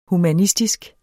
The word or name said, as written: humanistisk